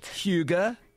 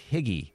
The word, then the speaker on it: hygge: And how do you say it? – Hygge. – Hygge? – Yeah, it doesn't look like that. It looks like higgy, but it's H-Y-G-G-E.